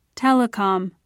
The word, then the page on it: telecom: British English